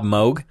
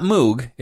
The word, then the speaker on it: Moog: It's M-O-O-G. It's about Bob Moog. It's not Moog. It's Moog. That's actually correct.